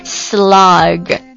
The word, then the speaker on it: slog: 来看第二单词，第二单词作为动词，它是表示埋头苦干坚持不懈的做什么事情，以及艰苦的往前面行走这样的意思，读作 slog slog S-L-O-G slog，它有一个 R 的趋势，也有一个 O 的趋势啊，就折中一下 slog slog。